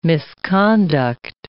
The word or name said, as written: misconduct